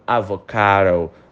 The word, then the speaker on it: avocado: I want an avocado. I want an avocado. I want an avocado, tá? Repara na pronúncia dessa palavra avocado. O D, ele tem um som de R.